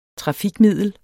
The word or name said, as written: trafikmiddel